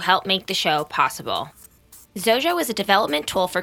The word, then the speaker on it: Xojo: Zojo is spelled like X-O-J-O.